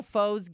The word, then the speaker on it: faux: It was zero foes given. But you know, it's spelled F-A-U-X, yes.